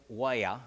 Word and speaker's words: weir: Now, they pronounce it Weir, but it's spelled W-E-I-R,